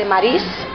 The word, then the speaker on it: Maryse: Es que el libro digital, solamente, es que quiero recomendar el libro digital, un libro que se llama La gata de Maris. Maris se escribe Maryse,